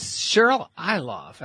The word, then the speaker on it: Cheryl Ilov: Her name is Cheryl Ilov.